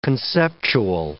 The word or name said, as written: conceptual